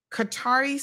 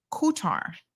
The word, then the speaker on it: Qatar: Okay, other Arabic speakers have explained that the English word closest to the native pronunciation might actually be guitar. In Gulf dialects, the first consonant in Qatar is more like a G than a hard C.